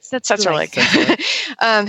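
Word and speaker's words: Tsetserleg: And so let's see, we had, you know, it was pretty fun at the fork to see Boyd Martin and Setzerleg, or however, I'm like, I still don't know how you say that word. – You said it right. Setzerleg.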